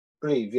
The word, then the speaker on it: Brive: Brive, yeah.